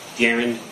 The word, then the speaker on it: Daryn: Darren, D-A-R-Y-N, Dupree, D-U-P-R-E.